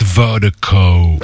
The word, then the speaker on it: VIRDIKO: And Vertigo is spelled V-I-R-D-I-K-O. That's V-I-R, that I can't speak. That's V-I-R-D-I-K-O. Pronounced Vertigo.